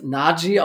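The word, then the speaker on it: Najee: Äh, zum Beispiel Naji, das hat er erklärt, das wird Naji ausgesprochen, war ihm ganz wichtig.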